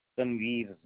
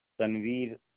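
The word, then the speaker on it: Tanwir: Tanveer Zafar. – Could you say that again for me? – Tanveer Zafar.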